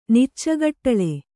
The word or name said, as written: ನಿಚ್ಚಗಟ್ಟಳೆ